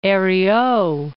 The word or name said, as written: Herriot